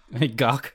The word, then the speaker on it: Gach: spelled G A C H. Would you pronounce that as Gak? Sure. Or is it yeah, yeah, that's fine. Oh, Gak.